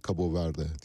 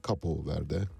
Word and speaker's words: Cabo Verde: Bu yüzden de Atlantik'e açılırken, Afrika'dan ya da Avrupa'dan Atlantik'e açılırken ilk sığınabileceğiniz liman orada Yeşilburun adalarında e, bildik adıyla Cabo Verde diye bilinir ya da Capo Verde, Cabo Verde diye de telaffuz ediliyor.